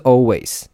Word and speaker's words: Alvvays: 好，第一首歌，呃，这个乐团好像我不知道怎么发音的，应该叫应该就是 Always，它是 A L V V A Y S，它就是 V V 变成 W W W。然后就变成 always 这样子，A L V V A Y S。